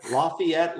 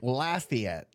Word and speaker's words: Lafayette: Lafayette, Louisiana. – Well, you almost got it right. Lafayette.